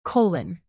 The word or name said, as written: colon